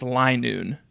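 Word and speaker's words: Linoone: I did have to look up how to pronounce Linoon. It seems like consensus is it's Linoon.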